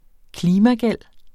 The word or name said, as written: klimagæld